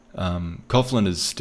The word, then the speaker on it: Coughlin: Coughlin, is how you pronounce it, is still a young lion, but he's really fired up.